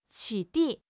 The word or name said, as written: 取缔